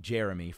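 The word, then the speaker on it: J'er'em'ih: In reference to Jeremy, spelt J apostrophe E R apostrophe E M apostrophe I H. – Uh-huh. – Pronounced like Jeremy from Dimension 20's side quest, Escape from the Blood Keep.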